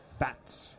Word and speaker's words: Pfaadt: P-F-A-A-D-T. Fatt.